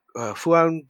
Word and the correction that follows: Falun: Gong, is it? I can't remember how that's pronounced. – Falun. – Falun? – Well, I don't know how it's pronounced, but it's spelled F-A-L-U-N in English. Falun Gong.